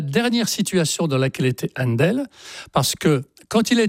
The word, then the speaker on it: Handel: Händel, H-A-N-D-E-L, ça se prononce tout naturellement Händel en anglais.